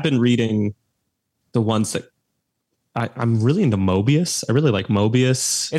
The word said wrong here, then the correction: Morbius: It's pronounced Morbius. – Morbius.